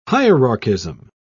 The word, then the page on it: hierarchism: British English